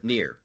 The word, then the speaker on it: NieR: I guess you pronounce it as Near. Is that right? Or is it Nier? – Yes. – It's Near Replicant. – Near.